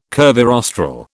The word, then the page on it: curvirostral: British English